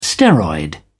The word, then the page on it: steroid: British English